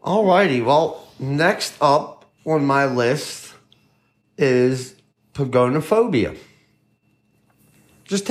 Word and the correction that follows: pogonophobia: Pagone. Spell that for me. – P-O-G-O-N-O phobia. – So pogonophobia.